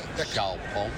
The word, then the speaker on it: Xiaopeng: Pronounce the name of the company. – Xiaopeng. Xiaopeng. – It's X I A O P E N G.